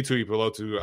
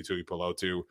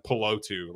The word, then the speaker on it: Tuipulotu: He's a USC guy, and he's getting Thule's name wrong left and right. Chopping the first half of his last name on it out, and just calling him Pelotu.